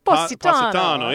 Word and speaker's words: Positano: I can't pronounce cities. – Positano? – Positano, Italy. Okay.